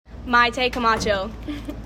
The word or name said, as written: Mayte Camacho